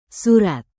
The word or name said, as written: surat